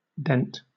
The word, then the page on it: dent: British English